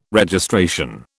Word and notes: Registration: British English

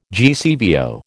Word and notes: GCVO: American English

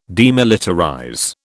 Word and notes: demilitarize: British English

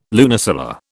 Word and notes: lunisolar: British English

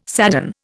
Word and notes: sadden: Indian

